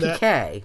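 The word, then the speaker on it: piqué: Like peak, P-I-Q-U-E, there's that fabric. Isn't PK. – Isn't that PK?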